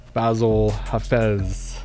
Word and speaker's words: Bassil Hafez: I'm here with the welterweight Fury FC champion of the world, Bassil Hafez.